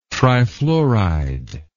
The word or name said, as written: trifluoride